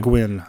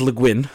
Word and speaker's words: Le Guin: Yeah, it's people try to pronounce it French. – Yeah. – But it's actually, in her own words, it's Welsh or something. – It's Welsh. It's Breton, originally Welsh. So it is Le Guin.